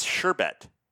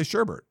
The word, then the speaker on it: sherbet: It's sherbet. – Is it? – It's S-H-E-R-B-E-T. – Oh. I guess I pronounced it wrong forever. But don't people, I think people say Sherbert though, right? – I think they do.